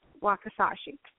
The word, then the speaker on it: Wakizashi: But and everybody has a hard time saying it, but it's exactly the way it's spelled, Wakizashi.